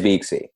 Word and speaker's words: Beaxy: It is Beaxy. Yeah. I always spell it like phonetically, like B E A K dash see, like for people that don't, you know, don't implicitly get it, right?